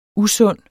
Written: usund

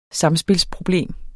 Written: samspilsproblem

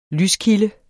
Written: lyskilde

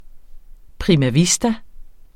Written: prima

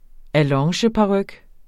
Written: allongeparyk

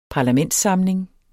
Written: parlamentssamling

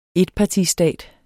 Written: etpartistat

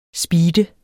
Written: SPEEDEDE

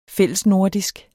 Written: fællesnordisk

